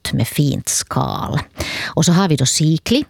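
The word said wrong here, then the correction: Sieglinde: eller Siglinde, hur den uttalas, Siglinde.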